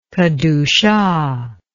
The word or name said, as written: kedushah